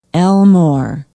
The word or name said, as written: elmore